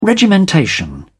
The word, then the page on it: regimentation: British English